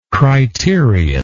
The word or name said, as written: criterional